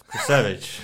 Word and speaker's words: Krusewicz: Krusewicz.